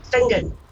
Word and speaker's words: Finzean: Place spelled F-I-N-Z-E-A-N. Benzian. Pronounced Fingen.